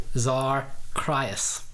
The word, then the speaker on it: Xarcrius: It is capital X-A-R, capital C-R-I-U-S. Zarkrius is how you pronounce it.